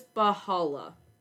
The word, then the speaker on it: Baha'u'llah: I believe it's Baha'u'llah. A primary theme of Baha'u'llah's teachings is achieving world peace through the establishment of unity, justice, and equality.